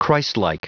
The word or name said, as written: christlike